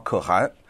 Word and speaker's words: 可汗: 首先刚才我们这位 YouTube 的观众 Late Close 说这个汗呢、出汗的汗，这个字应该念汗，没错，这个地方跟大家要讲清楚，成吉思汗或者是在蒙古的什么可汗啊，他写的是出汗的汗这个字，但是发音呢正确的是汉语的第二声啊。